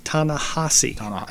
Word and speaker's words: Ta-Nehisi: And his name is actually pronounced Ta-Nehisi.